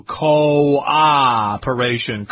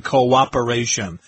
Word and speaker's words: cooperation: Cooperation is a tricky word here. We've got two O's there, but that's not one syllable. It's two. Cooperation. Cooperation.